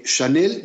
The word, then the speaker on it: Chanel: y que Chanel no es más que una derivación de Hannah, que al pasar a Francia, la CH se pronuncia precisamente como lo están ustedes escuchando.